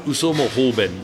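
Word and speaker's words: Uso mo hoben (嘘も方便): What comes to mind is a very Japanese phrase, uso mo hoben. – Okay. – Uso mo hoben. Am yeah. I saying I'm saying that correctly, yeah, right? That's correct. That's correct.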